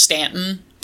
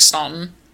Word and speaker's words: Staunton: Croatan Island, sometimes called Croatoan Island, is now known as Hatteras Island, and is located on the banks of the Staunton, it's pronounced Stanton, but it looks like Staunton, River.